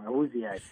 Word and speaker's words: Awuzie: Awuzie.